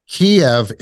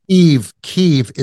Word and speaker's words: Kyiv: Kiev is the Russian pronunciation. – Right. – Yeah. – Kyiv is the Ukrainian pronunciation.